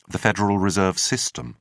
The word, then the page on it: federal reserve system: British English